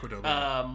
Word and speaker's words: Qdoba: Qdoba.